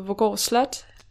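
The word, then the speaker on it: Voergård Slot: Det er V-O-E-R, ikke? Vorgård. – Ja. – Ja. – Det er fordi, at sådan en sjællandsk accent-agtig vil jo sige Vorgård Slot.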